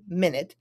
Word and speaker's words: minute: I'm also curious as to how the word M-I-N-U-T-E, minute, can also be pronounced minute, and that's an adjective meaning tiny. A minute amount of time. You know what? A minute is a minute amount of time. But when we say minute and not minute, when we pronounce it minute, it just means a tiny, small thing.